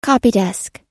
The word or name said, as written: copydesk